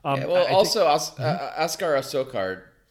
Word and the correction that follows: Askar Askarov: I'm sorry. Did I get his name right or did I screw it up? – Askar Askarov. – Askar Askarov.